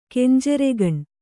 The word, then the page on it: ಕೆಂಜರಿಗಣ್ಣ: kenjarigaṇṇa